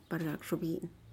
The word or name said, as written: pargak-xubiyi'in